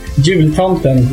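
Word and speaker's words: Jultomten: Jul Tomten. – What? – Jul Tomten, we call him.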